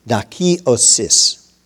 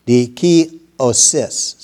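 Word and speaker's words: dikaiosis: Justification in this scripture is dakiosis. Yeah, that's how you pronounce it. It's D-I-K-A-I-O-S-I-S. Dikiosis.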